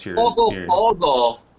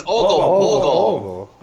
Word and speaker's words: Ogopogo: Ogopogo. You got to say it like a Canadian. Ogopogo.